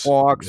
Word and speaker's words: Fawkes: I can never pronounce the last name right. Guy Fawkes. – Yeah. – Fawkes. – Fawkes. Yeah. Fox. – Isn't it like F-A-U-X?